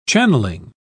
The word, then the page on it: channelling: British English